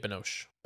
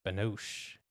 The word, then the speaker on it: Binoche: Juliette Binoche. – Binoche.